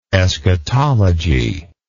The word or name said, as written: eschatologist